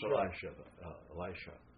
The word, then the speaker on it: Elisha: It's pronounced Elisha. Elisha. – But Elijah. – Yeah, Ja and Shah.